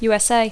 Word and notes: USA: English

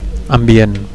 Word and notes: ambient: Catalan

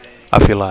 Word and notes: afilar: Catalan

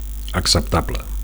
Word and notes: acceptable: Catalan

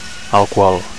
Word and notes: alcohol: Catalan

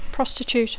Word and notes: prostitute: English

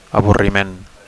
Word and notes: avorriment: Catalan

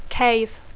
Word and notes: cave: English

